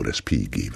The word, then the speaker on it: Respighi: Und dann sagte die Technikerin, entschuldige, wenn ich dich darauf hinweise, aber GH in Italienisch spricht sich immer wie G. Der heißt also Ottorino Respighi, wenn du den das nächste Mal ansagst.